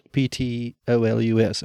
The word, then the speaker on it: Ptolus: It's spelled P T O L U S, am I right? – Mm-hmm. – But it's pronounced Ptolus.